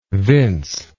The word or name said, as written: Vince